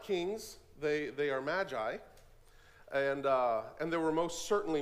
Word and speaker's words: Magi: This episode contains the story of the visit of the Magi, or it would be pronounced Magi, but that doesn't sound right in English, so we say Magi.